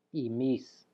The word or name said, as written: imis